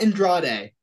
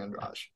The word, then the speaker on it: Andrade: Not Andrade. Every time I hear it, it's not Andrade. Look, I'm not the best at pronouncing names, so I'm not one to talk. But it's Jessica Andrade.